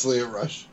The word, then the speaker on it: Lio Rush: It's it's Lio Rush.